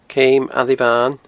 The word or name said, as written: Ceum a Libhain